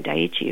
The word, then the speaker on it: Daiichi: and Fukushima Daiichi, if you say it correctly.